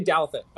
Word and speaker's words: Douthat: I'd say Douthat, but I don't know if that's I think that's what I've heard.